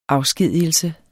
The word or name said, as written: afskedigelse